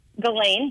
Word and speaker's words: Ghislaine: I can't say her name to save my life. Glacine. It's Ghislaine. There you go.